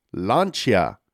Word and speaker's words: Lancia: Lancia is pronounced Lancia, and Abarth is pronounced Abarth.